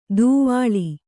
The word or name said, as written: ದೂವಾಳಿ